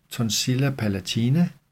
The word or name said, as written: tonsilla palatina